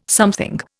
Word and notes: something: Indian

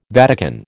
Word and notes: Vatican: American English

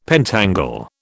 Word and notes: pentangle: British English

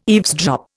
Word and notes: eavesdrop: Indian

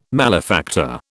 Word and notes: malefactor: British English

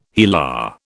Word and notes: HeLa: British English

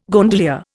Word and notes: gondolier: Indian

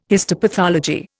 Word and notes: histopathology: Indian

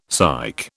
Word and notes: psych: British English

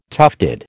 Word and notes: tufted: American English

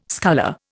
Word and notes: sculler: Indian

